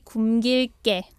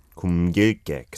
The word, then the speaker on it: 굶길게: It has to be 굶길게. – 굶길게, because it's got the double 받침 there, the liel and the miem on the first syllable. 굶. 굶. – Yeah, the ignore the first liel consonant.